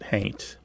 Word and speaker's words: haint: Somebody might spell it haunt, but pronounce it haint.